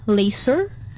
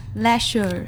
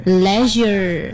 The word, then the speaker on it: leisure: And then the next words we have laser. – Oh no. – Lasers. – Leisure. – Leisure. – Yeah. – Okay, so the right word to pronounce leisure, mm-hmm.